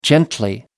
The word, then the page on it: gently: American English